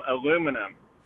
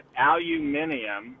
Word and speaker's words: aluminum: I pronounce aluminum aluminum. He pronounces it aluminium, which sounds completely wrong to me.